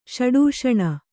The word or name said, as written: ಷಡೂಷಣ